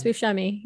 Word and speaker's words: Buscemi: Yeah, it's Buscemi.